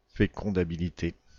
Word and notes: fécondabilité: /fe.kɔ̃.da.bi.li.te/; France; French

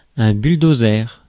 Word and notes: bulldozer: [ɛ̃ byl.do.zɛʁ]

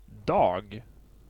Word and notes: dag: /dɑː(ɡ)/

